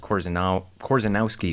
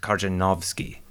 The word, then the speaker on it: Korzenowski: And then in my name, you have another combination of letters RZ, which for some reason makes the sound like envision or measure instead of R and Z, oddly enough. So my last name in Polish, it would not be pronounced Korzenowski, it would be pronounced Korzenowski. Measure in the middle.